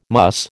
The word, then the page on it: muss: American English